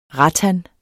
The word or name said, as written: rattan